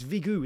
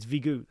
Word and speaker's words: Vigo: It's Vigo, isn't it? It's Vigo. That's the whole point. It's spelt Vigo, but I'm sure when I saw his show, he told me to pronounce it. He told us all to pronounce it Vigo. So I've been correcting people and saying it's Vigo.